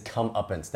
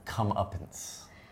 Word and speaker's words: comeuppance: He gets his comeuppance. That means he's been doing something bad, and then finally something happens, and now he's got to face his consequences. How do you say that again? – You got your comeuppance. – Comeuppance. Oh, you 不要这么难，我要想。